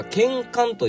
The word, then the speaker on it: 睍睆: で、その後半の漢字、二文字ですね、これは剣冠と読みまして、見た目が美しいという意味でございます。